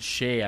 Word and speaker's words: Shai: Although I think it's pronounced Shay. I guess I've been. I think I've been saying it wrong the entire time.